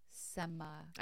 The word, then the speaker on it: semaglutide: I want to say semi-glutide. – The company that we work for reminded me of that. – Oh. Yeah. Okay. Semaglutide.